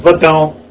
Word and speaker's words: Vodoun: Voodoo, it depends on you'll see a spelling, V-O-D-O-U-N. It's pronounced Vadon. It's like, it's got a nasalized French N.